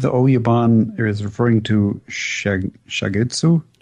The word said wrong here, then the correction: Oyabun: It's pronounced Oyabun. – Oyabun. That's exactly how Yukio says it.